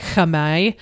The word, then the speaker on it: Khmer: So just as an FYI, Khmer, I paused during that because I was like doing my due diligence and like looking up what the Cambodian like pronunciation of Khmer is, which is like Khmer.